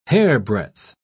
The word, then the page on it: hairbreadth: British English